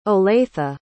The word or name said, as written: olathe